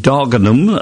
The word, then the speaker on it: Dagenham: And Margaret Mills returns to take us on a trip to Valence House in Dagenham, as I was told to pronounce it.